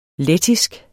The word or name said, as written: lettisk